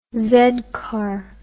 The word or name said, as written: z car